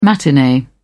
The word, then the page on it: matinee: British English